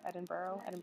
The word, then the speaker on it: Edinburgh: Then I'll be going up to Scotland, Edinburgh, which is how they say it here, Edinburgh.